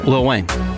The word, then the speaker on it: Lil Wayne: Tyga, T-Y-G-A. – Little Wayne. – Yeah. – Okay. – Lil Wayne.